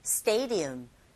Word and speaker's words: stadium: Stadium, 강세, 정확한 위치에 주고 하시면 되고요. 이때 D 사운드 약하게 발음되면서 얼핏 들으면 R 소리에 가깝게 들리게 되죠.